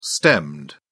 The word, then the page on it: stemmed: British English